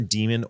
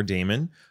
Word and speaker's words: daemon: So a note to anybody, it can be pronounced either demon or daemon. Apparently, they actually mean the same thing.